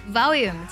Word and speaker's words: volume: Volume 这个单词呢，也可以读作 volume, 都是对，they're both right.